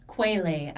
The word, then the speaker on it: Quetelet: BMI was created by this guy named Lambert Adolphe Jacques Quetelet. – Quelet, actually, is how you pronounce it.